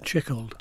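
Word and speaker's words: Tschichold: So one of your early influences, as well as Abram Gaines, was actually Jan Tichold, is that how you pronounce it? – Chickold.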